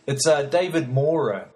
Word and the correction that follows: Maurer: I'm probably pronouncing it wrong. M-A-U-R-E-R. Maura. David Maura.